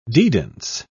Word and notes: dedans: British English

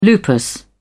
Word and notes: lupus: British English

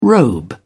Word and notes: robe: British English

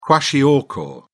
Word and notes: kwashiorkor: British English

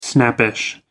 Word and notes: snappish: American English

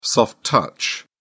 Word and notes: soft touch: British English

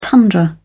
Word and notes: tundra: British English

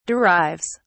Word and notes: derives: British English

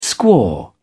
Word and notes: squaw: British English

